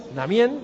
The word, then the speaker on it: Amiens: Mañana tenemos la quinta jornada del Tour de Francia, la quinta etapa entre Agas y Amiens. Namien, que la S no se pronuncia,